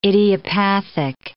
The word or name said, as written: idiopathic